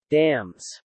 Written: dams

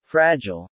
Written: Fragile

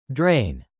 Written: drain

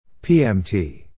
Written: PMT

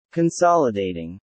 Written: Consolidating

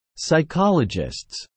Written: psychologists

